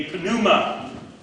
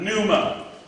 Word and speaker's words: pneuma: He pneuma. It's not pneuma. In the Greek, you pronounce the P, it's not silent.